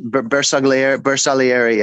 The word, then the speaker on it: Bersaglieri: I couldn't find, I can never pronounce the Italian elite forces, Bersaglieri. – Bersaglieri, yeah. – Good enough.